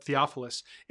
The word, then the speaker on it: Theophilus: I almost said his name wrong, but Theophilus.